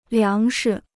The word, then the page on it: 粮食: liáng shi